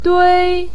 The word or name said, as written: dui1